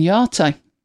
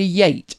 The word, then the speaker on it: Yate: A bit like Yeat and Yate. – Mm. – Why would you pronounce Y-A-T-E Yate? Where's the R? How would you know that? It's clearly yacht, as in yacht. But no, it's yate.